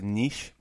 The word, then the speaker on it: niche: And he's very much, you know what, I didn't want to write a book that was too, the correct pronunciation is niche, but I go niche.